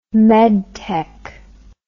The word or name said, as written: medtech